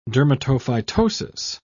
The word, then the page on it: dermatophytosis: British English